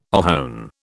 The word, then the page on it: ohone: British English